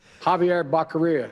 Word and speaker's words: Javier Becerra: Javier Baccaria. – It was actually rhymes with diarrhea. – Javier Baccaria.